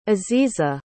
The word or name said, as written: aziza